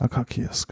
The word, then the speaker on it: Akakies: Dann steht halt hier ähm, Akassis, Akasis, würde ich sagen, wird es ausgesprochen, oder? – Mhm. – Ähm, Sparkling 2017. Ähm, genau dementsprechend. – Äh, Akakies. Ne? Akakies. Akakies. – Akakies, genau.